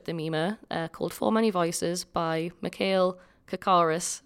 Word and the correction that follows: Karikis: I think I said it right. Did I? – Karikis. – Karikis. I said it again. – Mikhail Karikis. – Mikhail Karikis. We had this conversation just before recording and I still said it wrong, even after writing it down again. But hey ho. – Should do the phonetic. Oh. I've literally written K-A, the name Rick, and E-S.